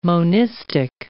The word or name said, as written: monistic